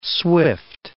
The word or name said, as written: swift